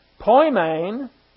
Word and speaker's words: poimen: Poimen in the Greek, P-O-I-M-E-N. This E is the eta. In Greek, it's pronounced like an A, so it's poimen didaskalos, and poimen is the Greek word for shepherd, one who serves as guardian, leader, or shepherd.